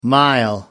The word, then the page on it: mile: British English